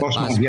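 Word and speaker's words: Bosma: Basma, do I say that correctly? – Basma, yes.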